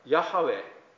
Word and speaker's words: ヤハウェ: アドナイと読まれている言葉は本来ヤハウェと読むのであろうとされています。